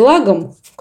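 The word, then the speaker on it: lagom: Тут это так пересекается, ну, скандинавские страны близкие, вот с той самой концепцией лагом, которой ударение на первый слог, которая как раз про умеренность.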